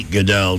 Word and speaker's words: Gaedel: Eddie Goodell, G-A-E-D-E-L. Rhymes with Bedell. But spelled differently.